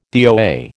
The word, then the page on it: DOA: American English